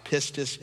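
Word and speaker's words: pistis (πίστις): It's the Greek word that's pronounced pistis in Greek.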